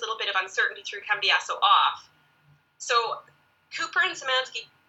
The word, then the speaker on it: Cambiaso: Everyone saw him consulting it and succeeding, but it turned out that he didn't have any information on Esteban Cambiaso. Is my pronunciation on that correct? – That's right, yep.